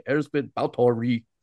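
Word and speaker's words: Báthory Erzsébet: And there are people out there who'll be like, you're not pronouncing it right if you don't say Erzbit Bautori.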